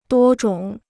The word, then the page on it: 多种: duō zhǒng